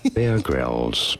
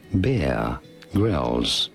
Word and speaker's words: Grylls: Bear Grylls. Pretty straightforward once you know it. Bear Grylls.